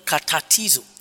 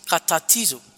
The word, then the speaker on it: katartizo: The word restore there is the Greek word katartizo. Katartizo. Katartizo is spelled K-A-T-A-R-T-I-Z-O. K-A-T-A-R-T-I-Z-O.